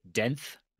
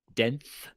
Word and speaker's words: denth: It's uh, I'm pretty sure it's denth, denth, like tenth, but with a D. Yep. – Yep. That's exactly how you say it.